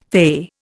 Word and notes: fey: Indian

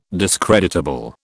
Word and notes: discreditable: British English

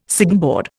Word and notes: signboard: Indian

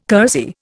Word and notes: kersey: Indian

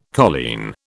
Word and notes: colleen: British English

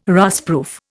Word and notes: rustproof: Indian

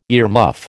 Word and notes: earmuff: American English

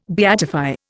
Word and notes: beatify: Indian

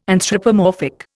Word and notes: anthropomorphic: Indian

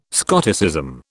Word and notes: Scotticism: British English

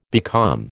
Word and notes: becalm: American English

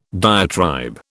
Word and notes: diatribe: British English

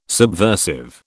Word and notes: subversive: British English

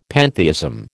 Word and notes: pantheism: American English

